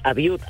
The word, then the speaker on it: Abiud: Bien, Abiud, Abiud. – Abiud, perdóname, Abiud. – ¿Cómo se escribe tu nombre? A-B-I-U-D, Abiud. – Así es, correcto.